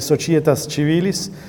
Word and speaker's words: societas civilis: I have the Italian church pronunciation, so I would say societas civilis, civitates, res publicas, populus, comitia.